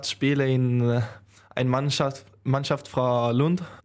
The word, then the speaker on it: Lund: Aus, wie spricht man das aus? Lund oder Lund? – Lund, ja.